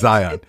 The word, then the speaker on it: Zion: And then we've heard from some very nice folks who live in Utah who pointed out that there is no Y in Zion, and it's Zion. How do you say it? – Zion. I've always said it right. – No, you haven't. – It's Zion.